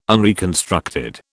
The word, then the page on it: unreconstructed: British English